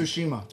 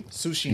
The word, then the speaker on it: Tsushima: Tsushima. – Thank you. – Tsushima.